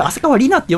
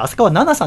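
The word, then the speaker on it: 浅川梨奈: 4位、浅川奈々さん。これ浅川里奈って読んでる人多いと思いますけど、私もそう読んでましたけど、浅川奈々さんでございます。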